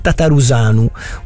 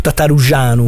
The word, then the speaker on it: Tătărușanu: E altro portiere, quindi il secondo portiere rumeno che abbiamo avuto è Ciprian Tătărușanu, o Tătărușanu, come, sì, se lo vogliamo pronunciare un po' alla rumena.